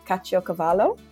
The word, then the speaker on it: caciocavallo: Over there, they did, and you're going to have to let me know how I go on my pronunciation here, but is it caciocavallo? – Great.